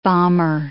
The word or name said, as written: bomber